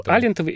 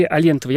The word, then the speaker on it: Алентовой: Думал, Алентовой или Алентовой. Я полез в справочники и нашел там два ударения.